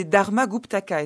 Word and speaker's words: Dharmaguptaka: Alors, c'est un extrait des Vinaya Pitaka des Dharmaguptaka. Est-ce que je le prononce bien ? – Bravo !